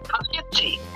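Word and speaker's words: Poughkeepsie: Pluskeepy, is that Pluskeepy? Is that how you pronounce it? – Pluskeepy. – Yeah, that's where he was from.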